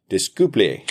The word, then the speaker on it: Disculpe: Disculpe.